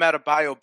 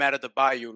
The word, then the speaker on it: Adebayo: Kendrick Perkins still calls Bam Adebayo Bam out of the Bayou like he's from fucking Louisiana.